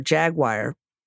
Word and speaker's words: Jaguar: And no one believed me that I pronounced Jaguar, Jaguar.